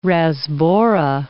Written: rasbora

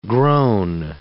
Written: groan

